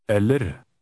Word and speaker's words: eller: Note that Norwegian words normally stress the first syllable. Word 11 begins with a short, stressed E. Try word 11. – Eller.